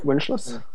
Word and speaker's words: Winschluss: Is Pinocchio by Winschluss? Does anyone know how to pronounce his name right? – I think that's right, Winschluss. – Yeah, it sounds about right.